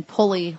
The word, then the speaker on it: Pulley: It's R-E-B-E-K-A-H. – Yeah. – And then Pulley, like the simple machine, P-U-L-L-E-Y.